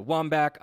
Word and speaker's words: Wambach: Susie Rance, when talking about the Seattle Reign, mentioned talking to Erica Dombach. She said on the audio, Erica Wambach, obviously.